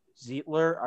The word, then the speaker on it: Zeitler: But Baltimore just really re-signing a bunch of their key guys and then signing the offensive guard, Kevin Zeitler. I think I'm saying that wrong. I said it right. Sweet. – Zeitler or Zeitler? I don't know. – Kevin Zeitler.